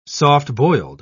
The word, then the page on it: soft-boiled: British English